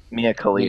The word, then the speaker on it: Mia Khalifa: Mia Khalifa.